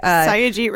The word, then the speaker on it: Satyajit: Satyajit Ray? – Yeah.